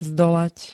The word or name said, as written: zdolať